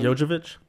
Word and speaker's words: Jovovich: How do you pronounce her last name? – Jovovich.